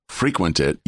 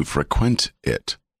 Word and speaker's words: frequent: You go to a place frequently, but if you go to a place frequently, you don't frequent it, you frequent it. It's a different pronunciation.